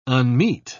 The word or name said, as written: unmeet